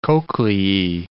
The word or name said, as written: cochleae